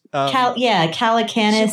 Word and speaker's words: Calacanis: Calacanis.